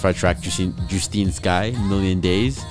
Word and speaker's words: Justine: Yeah, because your dumbass doesn't know how to pronounce Justine. It's Justine.